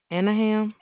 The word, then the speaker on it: Anaheim: They're located at, I don't know how to pronounce it. It's A-N-A-H-E-I-M. Anaheim. – Anaheim, yeah.